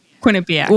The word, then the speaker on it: Quinnipiac: How do I say that? – Quinnipiac.